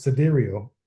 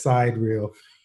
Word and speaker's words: sidereal: So I was today years old when I found out that it's pronounced sidereal. I've been reading it as side reel.